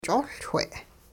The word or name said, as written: ch'oLchwe'